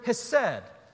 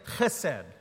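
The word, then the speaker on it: chesed (חסד): It's the word hesed. And if anybody knows how to speak Hebrew, that's a horrible pronunciation. Uh, it's more like, like chesed, like that. Uh, but, but I can't do it because I'm from North Carolina, so I'm not even going to try.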